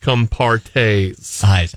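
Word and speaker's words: Compartés: Well, that's how it kind of spelt, but it's pronounced Compartes.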